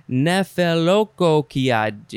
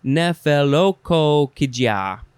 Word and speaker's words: Nephelokokkygia: N-E-P-H-E-L-O-K-O-K-K-Y-G-I-A. How do you say that? Nephelokokia. You know? Nephelokokia.